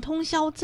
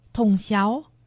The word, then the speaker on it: 通霄: 苗栗县通霄镇，客家话四线腔发音通霄，海陆腔发音通霄。